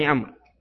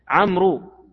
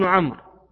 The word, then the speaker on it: عمرو: لكنها لا تنطق، هي الواو لا تنطق. يعني بعضهم يقرأ يقول عمرو، هذا غير صحيح، هو عبد الله بن عمرو